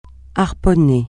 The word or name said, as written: harponner